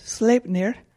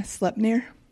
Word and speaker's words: Sleipnir: Yeah, I was saying Sleipnir, and it's Sleipnir.